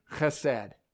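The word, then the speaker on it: chesed (חסד): If you want to write it down into English characters, it would be H-E-S-E-D. Chesed. If you pronounce it, it's kind of one of those in the back of the throat, kind of the like you're trying to spit up something, right?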